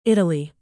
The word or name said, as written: Italy